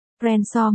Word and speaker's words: Renshaw: Cô Lancaster, anh Hooker, đây là điệp vụ liên bang Rickshaw. Darrow Renshaw không để mất nụ cười trong lúc chỉnh lại cách phát âm của vị cảnh sát trưởng. Renshaw mới đúng, anh ta nói, chìa tay ra cho cả Brett và Tori.